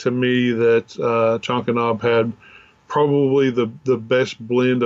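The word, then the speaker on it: Chankanaab: But we ended up going to Chonkanob. I think that's how you pronounce it. – Yeah, that's it.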